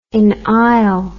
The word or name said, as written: inisle